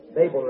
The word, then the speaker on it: Babel: Well, it's just like he was at Babel again. Babel, rather.